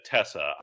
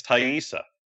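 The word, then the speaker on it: Taissa: I've always just said Tessa. – Me too. – I looked it up online. Apparently it's Thaisa.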